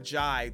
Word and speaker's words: Ghey: Uh, the murder of uh, the little transgender baby out there in uh, Manchester, England, um, uh, Brianna Ghey, which um, I had been pronouncing wrong for quite a bit there really all year if you think about it. Uh, but Brianna Ghey, the sentencing for the uh, two uh, folks that um, took her life uh, is set for February 2nd.